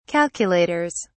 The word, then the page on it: calculators: British English